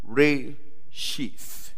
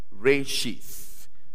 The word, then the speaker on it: Reshith: And I realize the word in Hebrew is pronounced Reshith. Reshith. Re as in R-A-Y. S-H-W.